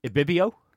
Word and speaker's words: Ibibio: Correct me on the pronunciation here. Ibibio. – That'll do.